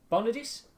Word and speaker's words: Bonaduce: Bonaduce.